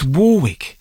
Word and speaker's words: Warwick: The one and only Dionne Warwick, and I met her backstage at a festival once and made the mistake of calling her Warwick. Yes, Dionne Warwick. How are you, Dionne? And got told off, yeah, because it's Warwick. You're supposed to pronounce it Warwick.